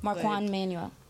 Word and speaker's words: Marquand Manuel: Mark, I don't want to pronounce his name. It's Marquand Manuel? – Marquand. – I apologize if I get this name incorrect. – Marquand Manuel.